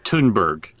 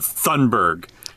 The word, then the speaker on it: Thunberg: Also, piece of shit, it's pronounced Thunberg. – Also, Greta. – Not Thunberg, asshole.